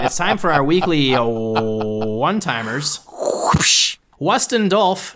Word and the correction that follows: Dustin Wolf: excuse me, that's Dustin Wolf, was named the AHL Goalie of the Month.